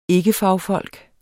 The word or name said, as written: ikkefagfolk